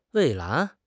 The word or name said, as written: 蔚蓝